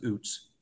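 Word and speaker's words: Ouzts: Robbie Oots, am I saying that right? I always screw it up. – Yeah, it's Oots. Yeah, they spelled it wrong.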